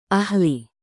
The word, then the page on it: ahli: indonesian